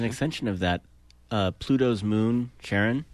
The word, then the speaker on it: Charon: So, but the discoverer always pronounced it as Charon, and he did it specifically because his wife's name was Charon.